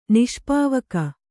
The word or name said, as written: ನಿಷ್ಪಾವಕ